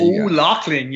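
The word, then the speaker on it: O'Lachlainn: Yeah, O'Loughlin, you nailed it.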